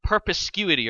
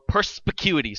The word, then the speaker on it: perspicuity: Perspicuity or perspicuity. Sorry, I said that wrong. Perspicuity.